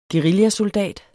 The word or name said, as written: guerillasoldat